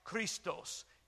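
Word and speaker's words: Christos (Χριστός): It's pronounced Christos, and it means Christ.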